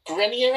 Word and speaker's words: Grenier: And then we've got some absolutely amazing performances from Adrian Grenier, I think you pronounce it.